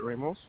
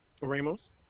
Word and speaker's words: Ramos: It's Robert Ramos. – Can you spell your last name? – Ramos. – Can you spell it? – Oh, I'm sorry. R-A-M-O-S.